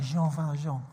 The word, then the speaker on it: Jean Valjean: So it's a musical, and there's a story about this thief called Jean Valjean. It's French. So you have to say it like that, Jean Valjean.